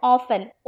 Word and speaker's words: often: இன்னைக்கு நீங்க பார்க்க போற வார்த்தை ஆஃபன், OFTEN. ஆஃபன் அப்படின்னா அடிக்கடி அப்படின்னு அர்த்தம். அடிக்கடி, யூ கம் ஆஃபன், நீ அடிக்கடி வா. ஆஃபன் அப்படின்னா அடிக்கடி, OFTEN. இத ஆஃப்டன் அப்படின்னு நம்ம ப்ரொனவுன்ஸ் பண்ணக்கூடாது, OFTEN, ஆஃபன், ஆஃபன்.